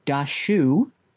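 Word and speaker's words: Daxu: I was going to say, it's not how it's spelled yes, yes. You would think it's spelled a different way, but it's D A X U.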